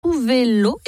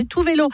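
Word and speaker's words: Tove Lo: La musique que dans les prochaines minutes, ce sera une collaboration entre la chanteuse suédoise Tuvelo, et oui, ça se prononce comme ça en suédois, j'ai cherché, Tuvelo, et le belge Stromae.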